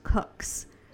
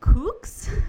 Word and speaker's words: cooks: But she pronounces it kooks. – Yeah. – And Eric is, like, screaming from the bed, how's it spelled? – Yeah. – And Fred's like, cook, like a cooker.